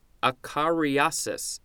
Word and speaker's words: acariasis: Moving on, acariasis, A-C-A-R-A-A. Did I say that right? A-C-A-R-I-A-S-I-S. Acariasis.